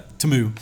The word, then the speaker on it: Temu: You weren't buying them on Temu like you can now. – Yeah. – Temu. Yeah, this crazy Super Bowl ruined it for me. My kids were like, what? That's how you say it? Temu.